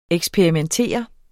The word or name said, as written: eksperimentere